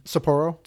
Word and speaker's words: Sapporo: Sapporo? – Sapporo, yeah.